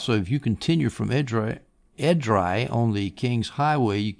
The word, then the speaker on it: Edrei: E-D-R-E-I, Edrei, I guess you pronounce it.